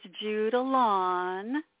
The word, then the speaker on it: Judilyn: Well, it's got to be a little more southern there, Tom. It's Judilyn.